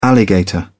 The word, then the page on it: alligator: British English